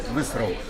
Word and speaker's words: Visceral: На этот раз об игре по «Звездным войнам», которую разрабатывает студия Visceral, о которой никто давно ничего не слышал. Можно, пожалуйста, не смеяться, так оно и произносится.